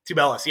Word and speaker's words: Tubelis: Might butcher his name, but Azulis Tubelis, am I saying that right? – Velas. – Tubelis, yeah, he's also nasty.